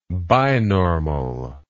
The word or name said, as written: binormal